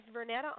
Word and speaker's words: Vernetta: And so we have Miss Vernetta on the phone.